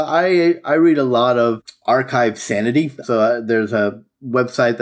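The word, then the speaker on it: arXiv: So Archive Sanity, it's spelled RXIV,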